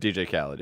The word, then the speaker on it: Khaled: Khaled? – DJ Khaled, yeah.